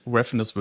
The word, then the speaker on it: Refenes: wie spricht man den Nachnamen aus? Refenes? – Refenes würde ich sagen.